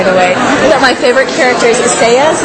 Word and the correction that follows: Sazed: Is that how you... – I say Sazed. Sazed.